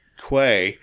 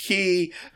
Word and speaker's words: quay: Yeah, one night I walked past the gaming room at home, and Tieran's gaming group was in there, and I heard someone say Quay, and Eileen did it. I said, it's pronounced Key, and they all looked around like, what? – Just...